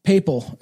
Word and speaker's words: papal: This is Poppy. – Papal, that's how you pronounce it.